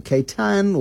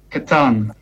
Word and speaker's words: Catan: Catan, C-A-T-A-N, or Catan, or Catan, or how do you pronounce it? – Catan.